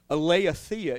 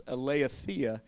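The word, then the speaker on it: aletheia (ἀλήθεια): That word is aletheia is how you pronounce it. That's in the Greek. The word is aletheia,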